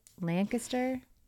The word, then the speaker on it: Lancaster: Strange that you don't pronounce it. How would you not? – Lancaster? – Lancaster. Yeah, yeah, if you were to just look at it and read it phonetically, it's definitely regional that you would even know not to.